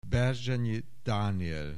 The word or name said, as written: Berzsenyi Daniel